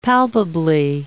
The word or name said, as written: palpably